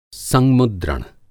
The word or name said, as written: संमुद्रण